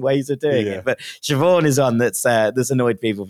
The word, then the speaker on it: Siobhan: And we'll start with Siobhan, which is my aunt's name.